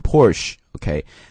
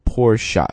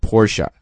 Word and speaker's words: Porsche: 第一个是保时捷啊，叫 Porsche 啊，注意一下这个单词的读音，很多人呢会把它读成 Porsche，OK，、 okay? 其实这个单词应该读 Porsche，它是两个音节，Porsche 啊，保时捷，Once again Porsche，OK、 okay?。